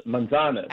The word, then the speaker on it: manzanas: Manzanas. Yes. – Manzanas. – Manzanas. Manzanas. What does that mean? – It means manzanas. Apples. Apples. – Manzanas. Apples? – Manzanas.